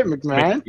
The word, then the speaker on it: McMahon: How do you say his name? – McMahon.